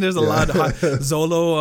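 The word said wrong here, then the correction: Xolo: Yeah, it's pronounced Cholo. – Oh, is it? – Yeah. – Is it Cholo? Is it? Yes, no, it's Cholo.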